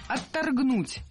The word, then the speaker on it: отторгнуть: По крайней мере, там достаточно было показано того, что должно оторгнуть от этой, от такого, от какой карьеры в сфере древнейшей профессии. – Отторгнуть. Неверно. Правильно, отторгнуть. Ударение в этом слове ставим на второй слог.